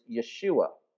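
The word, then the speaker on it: Yeshua (ישוע): Now, the Hebrew way of pronouncing Joshua is Yeshua.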